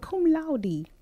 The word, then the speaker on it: laude: So just like at my son's graduation, they said cum laude. And I'm like, it's cum laude. – So there's a D, laude, yeah. Mm-hmm. Two syllables. – Yes. – Because they're E at the end.